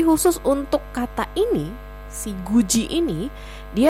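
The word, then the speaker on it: guji (굳이): Jadilah tulisannya good i atau gudi tapi pelafalannya menjadi guji. Ya, hati-hati ya. Diget plus suku kata i itu menjadi ji. Oke.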